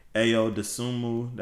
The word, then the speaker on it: Ayo Dosunmu: It's gonna be Cade Cunningham, Josh Giddey, Chris Duarte, Ayo Dosunmu, that's his name.